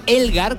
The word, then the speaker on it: Elgar: Elgar, que se escribe como suena, E-L-G-A-R, Chanson de Matín.